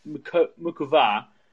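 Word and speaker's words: Muchová: She lost to Karolina Mukova this week, as I'm told you're supposed to correctly pronounce it,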